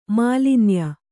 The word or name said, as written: ಮಾಲಿನ್ಯ